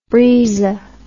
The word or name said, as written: Briza